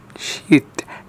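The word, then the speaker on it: Scheidt: Sheet.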